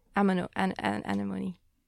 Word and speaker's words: anemone: And this is actually a wood ammonia? Anemone. That's what I anemones, yeah.